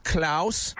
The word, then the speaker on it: Klaus: Yes, Klaus. – How do you spell that? – It's K-L-A-U-S.